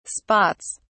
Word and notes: spots: British English

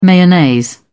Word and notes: mayonnaise: British English

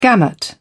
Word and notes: gamut: British English

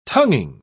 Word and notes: tonguing: British English

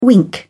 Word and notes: wink: British English

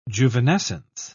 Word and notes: juvenescence: British English

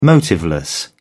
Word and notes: motiveless: British English

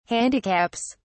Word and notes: handicaps: British English

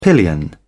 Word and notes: pillion: British English

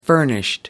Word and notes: furnished: American English

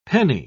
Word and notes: penni: British English

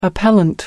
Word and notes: appellant: American English

